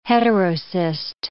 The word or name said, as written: heterocyst